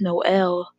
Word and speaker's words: Noel: And Noel, he's funny, too. No. I'm going to call him No. Even though that's not how you say his name, I'm pretty sure. It's Noel. Whatever fucking race he is.